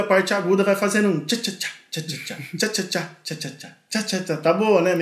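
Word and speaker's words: chá-chá-chá: E o tchá-tchá-tchá, como é que fala tchá-tchá-tchá? É assim mesmo, né? Tchá-tchá-tchá. Assim mesmo, assim mesmo.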